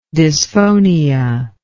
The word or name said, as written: dysphonic